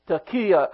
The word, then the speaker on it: taqiyah: And he says, taqiyya again. Taqiyya is spelled T-A-Q-I-Y-A-H.